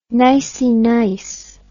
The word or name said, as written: nicey nice